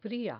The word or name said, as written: fria